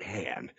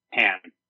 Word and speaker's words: Han: They just it just there was not that as much chemistry as Solo and uh, oh by the way, it's pronounced uh, Han, not Han. Han.